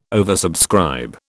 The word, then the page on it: oversubscribe: British English